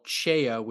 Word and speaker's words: CEIA: Dave Ellis is Field Applications Engineer for a company called CHEIA, which is spelled CEIA International.